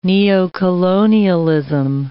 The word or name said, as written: neocolonialism